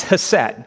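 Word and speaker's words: hesed (חסד): If you're a Jewish person or a Hebrew speaker, you would say it with a clearing of your throat, like hesed. That's actually how that first letter is pronounced, but it's hesed.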